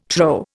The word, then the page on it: trow: Indian